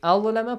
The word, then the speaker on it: أظلم: أظلم pronunciation is it's a heavy letter.